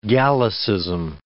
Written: Gallicism

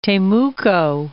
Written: Temuco

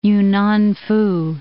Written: Yunnanfu

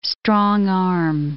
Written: strong-arm

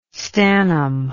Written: stannum

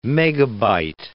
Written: megabyte

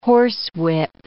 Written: horsewhip

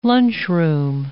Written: lunchroom

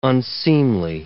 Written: unseemly

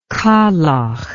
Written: kalakh

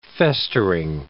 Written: festering

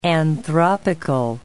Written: anthropical

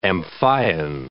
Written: Amphion

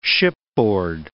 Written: shipboard

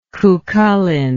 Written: Cuchulainn

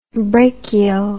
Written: brachio

